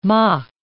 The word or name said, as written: mock